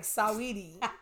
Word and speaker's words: Saweetie: Yeah, I was like, Saweetie. I intentionally am going to emphasize the S-A. I'm like, nah, you put that A there, bitch. You about to be Saweetie.